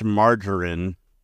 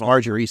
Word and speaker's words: margarine: Yeah, I like my mom would always make that with too much brown sugar, too much margarine, and then the it's pronounced margarine.